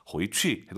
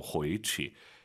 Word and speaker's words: 回去: 回去 해도 되지만 경성으로 回去.